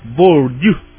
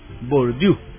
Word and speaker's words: Bourdieu: Se escribe B-O-U-R-D-I-E-U, pero se pronuncia Bourdieu, Bourdieu, no es Bour, sino que Bourdieu, ¿verdad?